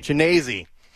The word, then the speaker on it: Chianese: Chianese.